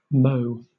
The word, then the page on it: mot: British English